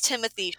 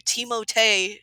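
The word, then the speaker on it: Timothée: So I was like, it is Timothy Chalamet, right? Which then led me to, that's actually Moji? Not how you pronounce his name. – Yeah. – It's Timotei. It's like, I'm not going to do that, but it's Timotei Chalamet.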